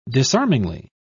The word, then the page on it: disarmingly: British English